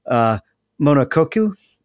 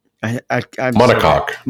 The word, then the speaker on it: monocoque: Ringmakers is selling a set of plans for an F1 style monokoku. – Monocoque.